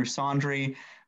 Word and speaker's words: Saundry: My handle is just at Andrew Saundry. My last name is spelled S-A-U-N-D-R-Y. It's the same as laundry, but replace the L with an S.